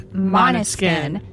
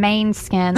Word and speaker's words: Måneskin: Yeah, and I did accidentally mispronounce the name when I was introducing them, so it's Måneskin. – Yes. – Not main skin, like I said.